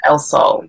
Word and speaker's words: El Sol: please forgive me if I'm pronouncing this wrong, but El Sol, is that right? – Yeah, El Sol, yeah.